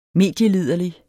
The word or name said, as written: medieliderlig